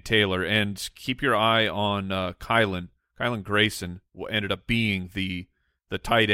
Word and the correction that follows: Granson: Did I say his name wrong? Granson? – I thought it was Granson. – Yeah, it is Granson.